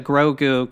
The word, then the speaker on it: Grogu: Don't Grey, it's not Grey Goo. Yeah. Grogu. – Grogu. – Yeah, Grogu. – Say it shows It depends on how much weight you put into the Ahsoka Grogu conversation, because she's able to, you know, gather.